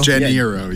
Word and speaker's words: Janeiro: Janeiro, yeah. – Janeiro. – I think it's Rio, too. Rio de Janeiro. – Like it rhymes with De Niro.